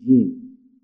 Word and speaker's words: جیم: ولی وقتی خواستیم تلفظ بکنیم نمیگیم جه، بگیم جیم. نمیگیم به، میگیم با.